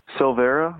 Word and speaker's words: Silveira: How do you say your last name? Silveira. That's S I L V E I R A.